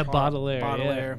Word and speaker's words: Baudelaire: Baudelaire. Is that how you say it? – Yeah. – Because I know it has something to do with that poet. – Yeah, Baudelaire.